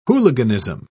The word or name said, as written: hooliganism